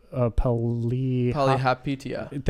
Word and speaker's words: Palihapitiya: It's Chamath Palihapitiya.